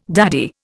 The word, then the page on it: daddy: Indian